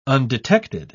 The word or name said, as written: undetected